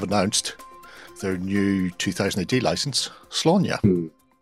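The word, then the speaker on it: Sláine: It's not pronounced Slain, because I see it all the time. – Slaanya. – Slaanya like Slaanya. – Exactly. – It's kind of like my Aunt Grania.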